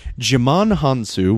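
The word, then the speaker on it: Djimon Hounsou: This is actually a 2015 film starring Jimon Hansu, which, by the way, is how you pronounce his fucking name.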